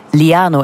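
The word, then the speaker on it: llano: En dat is dus liano. Ik ga het zo uitspreken, want het is blijkbaar Spaans. Een dubbele L is een LJ.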